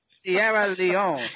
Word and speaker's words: Sierra Leone: Sierra Leone.